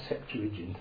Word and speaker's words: Septuagint: S-E-P, like in September. S-E-P-T-U-A-G-I-N-T. G-I-N-T.